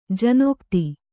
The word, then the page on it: ಜನಕತೆ: Kannada